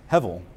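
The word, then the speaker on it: Hevel (הבל): The text doesn't tell us anything about his name, but in Hebrew it's pronounced like this, Hevel.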